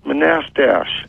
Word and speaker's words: Manastash: Do you know where Manistash? – Yeah, it's pronounced Manastash.